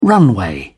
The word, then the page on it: runway: British English